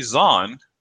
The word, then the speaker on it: Zahn: So, Timothy Zahn, which rhymes with Thrawn, has a new book called Thrawn that came out within the last, like, I think it came out last month.